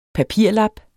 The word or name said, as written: papirlap